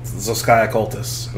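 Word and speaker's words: Zos Kia Cultus: But we want to just go in order, starting with, who the hell knows how to pronounce that? – Zochaeus Cultus. – Zochaeus Cultus, okay.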